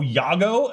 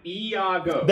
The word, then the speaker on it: Iago: There's an I. Iago. – There we go. – Iago? – Iago. But he always just is like, Iago. He's like, yeah, he just said it right there. – No, but it's like mixed into one. – Iago. I mean, it rolls off, but it's too silly. Iago. – But I feel like it doesn't have that. – There you go. – Yeah, Iago. I know how it's spelled. That's why I said two into one, not Y.